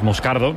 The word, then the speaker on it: Moscardo: Como bien ha pronunciado Unai, es Moscardo, es brasileño, sí.